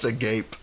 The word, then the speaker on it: agape: It's pronounced agape.